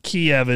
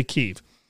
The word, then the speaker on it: Kyiv: It's Kyiv, right? Because I know that Kiev is like the Russian pronunciation for it, and Kyiv is the Ukrainian pronunciation. So I'll say Kyiv.